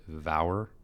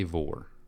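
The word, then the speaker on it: Vour: It's a V-O-U-R, so I've pronounced it Vower, but it's Trey Vore.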